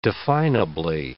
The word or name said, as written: definably